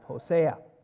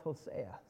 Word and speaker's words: Hosea: Or I listened to a message this week from it in which he called it Hosea. I'd never heard that before. I've always heard it pronounced Hosea.